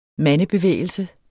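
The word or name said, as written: mandebevægelse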